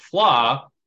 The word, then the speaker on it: Fleadh: Collins Dictionary of English defines Fleadh, pronounced Flah, as a festival of Irish music, dancing, and culture.